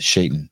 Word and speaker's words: Shaitan: Shaitan. That's that rhymes with Satan.